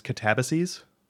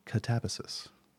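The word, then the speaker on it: katabasis: As I've been calling it, Catabasis. Apparently, I've learned that the other popular pronunciation is Catabases, which I hadn't really heard. But what about you? Upon looking it up, at least the Greek, like the Greek epic, it's Catabasis. – Oh wow! – Catabasis is the actual pronunciation we figured out in some back and forth on Twitter.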